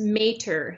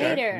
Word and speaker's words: Mater: And it's Mater, so M-A-Y. – Okay. Mater. We're close. Okay. – All right. Like Tom Mater without the T.